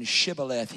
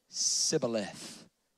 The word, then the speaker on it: Shibboleth: And instead of saying Shibboleth, he said Sibboleth.